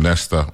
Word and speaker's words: Nesta: And by the way, they say his name Nesta means wise messenger.